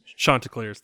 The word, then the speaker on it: Chanticleers: Apparently I mispronounced Chanticleers. – Chanticleers. That's right. It's hard.